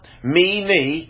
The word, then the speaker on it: Mene: And he said in verse 26 of Daniel 5, "This is the interpretation of the thing, Mene, M-E, with a long E, Mene, Mene, God hath numbered thy kingdom and finished it."